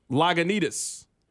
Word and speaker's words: Lagunitas: How do you pronounce it? – Lagunitas? – Lagunitas. Yeah, that one.